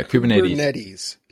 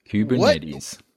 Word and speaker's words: Kubernetes: Yeah, Kubernetes. Kubernetes.